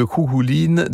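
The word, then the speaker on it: Cú Chulainn: C'est Julia Lebrun qui est allée fouiller tout ça, aller puiser des éléments de la vie de Kouhouline dans, encore une fois, ça s'écrit Kouchoulaïn, hein, dans les 76 récits en question.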